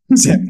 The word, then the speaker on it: Samuel: Samuel. – Samuel, right? The French pronunciation.